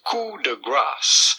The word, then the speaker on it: coup de grâce: Do not pronounce the P. Do not say coup, but rather coup de grâce.